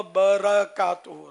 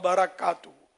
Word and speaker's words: برکاتہ: وہ برکاتہ را پر زبر آئے گا، وہ برکاتہ،